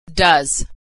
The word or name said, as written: does